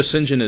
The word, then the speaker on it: St. John: Sinjin is St. John. That's how it looks, like, written out, St. John, but it's pronounced Sinjin.